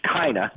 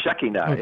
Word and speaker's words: Shekinah: Some of us from the South may pronounce it Shekinah, right. – But it's actually Shekinah.